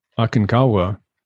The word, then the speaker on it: Aconcagua: So Kilimanjaro is 19,300 feet, not a particularly technical climb, but Aconcagua, if I'm pronouncing that correctly.